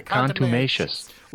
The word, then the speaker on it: contumacious: Contumacious. – Like contemplative and like tenacious.